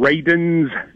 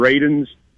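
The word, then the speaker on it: Radunz: Dylan Raidens, and that's how you say his name, Raidens.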